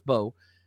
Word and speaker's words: Mbow: Marcus, it's spelled M-B-O-W, but the M is silent, so it's Marcus Bowe.